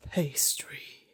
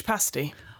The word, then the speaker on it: pasty: I believe it's pronounced a Cornish pastry. Yes, I would be one of the people if I had one of those. So a Cornish pastry for me, please. Or as I think they pronounce it better down there, a Cornish pasty.